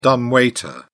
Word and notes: dumb waiter: British English

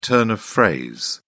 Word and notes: turn of phrase: British English

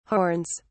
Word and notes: horns: British English

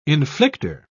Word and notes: inflicter: British English

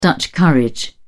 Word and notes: dutch courage: British English

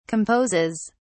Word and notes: composes: British English

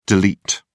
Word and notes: del: British English

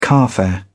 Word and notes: carfare: British English

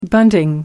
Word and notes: bunting: American English

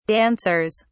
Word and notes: dancers: British English

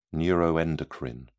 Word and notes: neuroendocrine: British English

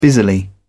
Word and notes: busily: British English

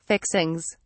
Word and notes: fixings: British English

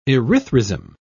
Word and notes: erythrism: British English

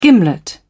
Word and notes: gimlet: British English

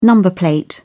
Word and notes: number plate: British English

